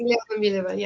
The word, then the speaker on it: Dilyana Mileva: Dilyana Mileva, yes.